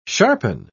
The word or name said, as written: sharpen